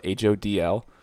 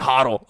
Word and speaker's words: HODL: And in the Dogecoin thing, like, everyone says hold, but they spell it HODL. So it's just like hodl, hodl, hodl.